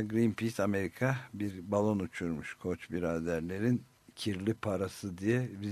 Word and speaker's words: Koch: Koç biraderler var işte Amerika'nın, yani KOCH diye yazılıyor ama Koç okunuyor, Koh değil yani.